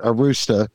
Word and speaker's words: Aroostook: And it's actually spelled Aroostook. But people, you know, they pronounce it Aroosta.